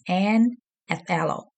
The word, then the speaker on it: anathallo: And the word revive in the Greek is anathalo, and you spell it anathallo. Again, it's anathalo.